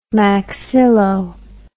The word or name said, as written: maxillo